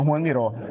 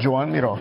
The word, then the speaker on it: Joan Miró: Mas, que ele também chama Juan Miró. Mas ele só é conhecido, ele só gosta de ser chamado por Joan Miró. – Ele preferia ser chamado de Joan Miró. Tá certo. – A pronúncia correta é Joan Miró.